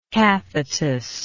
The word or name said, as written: cathetus